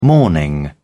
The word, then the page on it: morning: British English